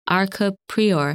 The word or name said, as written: Arkab Prior